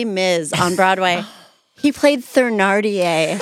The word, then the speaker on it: Thénardier: I did do a bunch of Broadway shows, but I did Les Mis on tour, and it's Thénardier with a hard T, even though it is spelled with a TH.